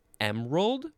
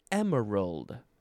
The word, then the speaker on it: emerald: You can say emerald or emerald. I believe that is correct, right? Emerald, yep. E-M-E-R-A-L-D.